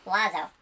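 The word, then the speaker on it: oiseau: And, like, go to Google Translate and look at the word for bird and know that it's pronounced wazo.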